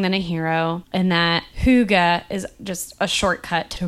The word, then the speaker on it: hygge: Hug. It sounds like the English word for hug. – Aww. – Which, which is thought to have roots in making oneself snug. So it's pronounced hygge. Hygge.